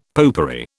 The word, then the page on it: popery: British English